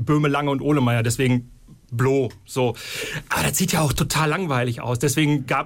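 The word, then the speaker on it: Blø: Und zusammen seid ihr Blo, gesprochen, geschrieben, aber mit einem Strich durchs O, der aber jetzt keine Auswirkungen auf die Aussprache hat, habe ich ja gelernt.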